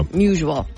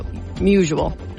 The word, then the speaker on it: Musial: Musial? – Yeah. – Musial. – Like usual. – Usual, not musical. Musial.